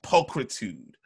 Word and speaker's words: pulchritude: P-L-P-U-L. – Uh-huh. – C-H-R. – P-U-L-C-H. – P-U-L-C-H-R. – Oh, pulchritude. – It's pronounced pulchritude. Like Christ.